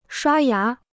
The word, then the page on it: 刷牙: shuā yá